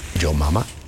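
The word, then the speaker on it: Jo Mama: En esta agrupación llamada Yo Mama, así se escribe, J-O Mama, Yo Mama, formados en Los Ángeles, California, en 1970, grabaron dos discos, uno en el 70, otro en el 71.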